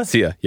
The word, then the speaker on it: Sia: Is it Sia? – Sia, yeah.